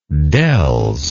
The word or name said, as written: dells